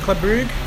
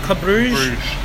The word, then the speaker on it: Bruges: Is that how you say it? Club Brugge? Club Bruges? – Bruges, I think.